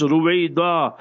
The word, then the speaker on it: Ruwaydha: So therefore, if you're spelling it and pronouncing it correctly, and that is how it's supposed to be pronounced, Ruwaydha, R-U-W-A-Y-D-H-A, Ruwaydha.